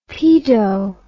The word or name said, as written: paedo